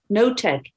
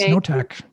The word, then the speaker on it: Knotk: I'm not sure how you pronounce it. Notek, K-N-O-T-K. – Yes, Notek, yeah. – You don't pronounce the K.